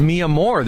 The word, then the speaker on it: Mi Amor: That's pronounced Mi Amor, this food truck here. – Yeah, wow. I love in Spanish.